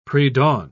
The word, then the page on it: predawn: British English